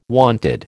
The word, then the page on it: wonted: American English